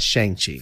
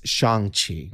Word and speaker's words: Shang-Chi: So Shang-Chi is pronounced, I found out. It's not Shang-Chi. Mm-hmm. It's Shang-Chi. – Mm. Shang-Chi? – No. Shang-Chi.